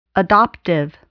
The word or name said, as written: adoptive